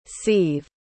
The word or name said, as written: sive